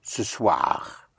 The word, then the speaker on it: ce soir: In French you say this evening, which is ce soir. Ce soir. Ce soir, like in soirée, soir, ce soir, this evening or tonight.